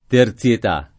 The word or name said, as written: terzietà